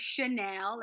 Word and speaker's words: Shanel: For some of you that don't know, middle name is Chanel. That's spelled S-H-A-N-E-L.